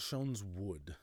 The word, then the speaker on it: Schoenswood: At Schoen's Wood. Okay. Schoen's Wood is spelled Schoenswood. Schoen's Wood.